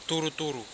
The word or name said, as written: turuturu